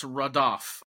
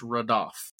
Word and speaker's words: radaph: It's raḏap. R-A-D-A-P-H is how it's transliterated, but pronounced raḏap.